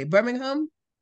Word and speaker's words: Birmingham: Well, I'm glad you were able to join us from Birmingham. I want to pronounce it correctly. Birmingham, England, right? – Absolutely spot on.